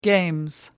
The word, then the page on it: games: British English